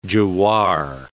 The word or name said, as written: jowar